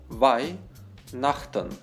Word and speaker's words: Weihnachten: Буква «Х» после «И» не читается. Weihnachten.